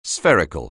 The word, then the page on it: spherical: anglais